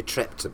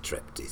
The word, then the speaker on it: Trepte: It really should be Trepte because it's a German name, but nobody actually pronounces it like that. Everybody says Trepte, so you're right on both counts.